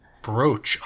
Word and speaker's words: brooch: And then how do you pronounce B-R-O-O-C-H? I heard that in another podcast. – I think it's... Oh. I guess, like, looking at it phonetically, I would say brooch, but it is a brooch, isn't it? Because isn't there a brooch, like, the things that you, like, clip onto your lapel? – Yeah. – Isn't that a brooch? But is that B-R-O-A? – No. It's B-R-O-O.